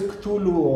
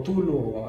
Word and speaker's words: Cthulhu: Que lo más parecido es Cthulhu o Tulu.